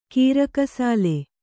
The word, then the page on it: ಗೀರಕಸಾಲೆ: gīrakasāle